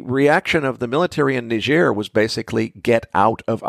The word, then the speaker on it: Niger: Now, the backstory here is Niger, and if you're not sure what I'm talking about, the West African country that's uranium-rich and looks to English speakers like it ought to be pronounced Niger. Well, that's a French-speaking place, so it's pronounced Niger. And their situation, they had a coup, essentially.